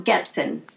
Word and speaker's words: Goetzen: It's pronounced Goetzen.